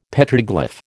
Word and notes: Petroglyph: American English

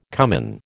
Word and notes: cumin: American English

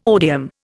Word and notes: odium: Indian

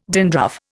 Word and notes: dandruff: Indian

